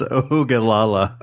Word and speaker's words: Oglala: It's pronounced Oglala. – Oh, well, it's O-G-L-A-L-A. Oglala. Oglala? – Yeah.